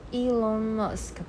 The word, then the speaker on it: Elon Musk: Elon Musk 吧，对啊，伊隆马斯克，对，好，所以意思是说，其实这个公司很早的时候，他早期投资人就进来了。